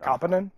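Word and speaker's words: Kauppinen: And at Bellator London, Melvin Manhoef, still out here somehow, he'll be fighting Kent Kopipnin. I probably butchered that. – Kopinen, I think his name is.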